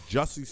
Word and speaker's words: Jussie: Yeah, but yeah, man, Kells is out again, and Jussie Sumlet, we got his name wrong. We kept calling him Jesse. It's Jussie. It's Jussie.